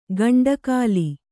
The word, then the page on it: ಗಂಡಕಾಲಿ: kannada